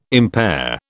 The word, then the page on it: impair: British English